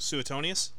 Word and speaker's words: Suetonius: Salunicus? – Who? Suetonius? – Yeah, that's him.